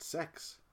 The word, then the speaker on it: CEX: Context, CEX is a second-hand shop in the UK. We'll post a link in the description. – Pronounced sex. – And in the adverts, it says, shop at sex.